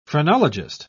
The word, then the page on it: phrenologist: British English